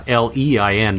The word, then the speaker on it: Lein: and just type in either my uh, name, which is uh, L E I N, by the right.